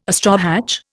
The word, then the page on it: astrohatch: Indian